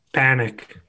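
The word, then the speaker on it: Panik: I was going to say, isn't like, how do you say his name? Richard Pink. – Panic. – I couldn't hear it. Ty Siri came up. – Panic. – Richard Panic. – Panic. Okay, it is Panic.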